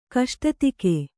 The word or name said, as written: ಕಷ್ಟತೆಗೆ